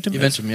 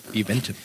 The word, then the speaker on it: Eventim: Das ist, ich lese auch manchmal so bei wie heißt das, Eventim? – Eventim, ja, ja. – Oder spricht man das? – Eventim.